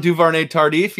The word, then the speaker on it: Duvernay-Tardif: Kansas City Chiefs, Lavernay Tardif. – Laurent Duvernay-Tardif.